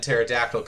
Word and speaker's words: Pterodactyl: With a P in front of Troy and Pterodactyl, because the P is silent, said, the power to expedite time, I guess.